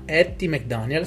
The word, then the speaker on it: Hattie McDaniel: Andiamo a concludere ragazzi con il 2006 che eh, in America fu eh, creato il francobollo di Etty, Atti, non so come si pronuncia, scusatemi, Etty, io dico Etty McDonald McDan- Hattie McDaniel, scusatemi, che fu la prima attrice di colore a ricevere l'Oscar nel 1940.